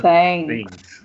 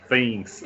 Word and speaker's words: Things: Stranger Thanks. Things. Things.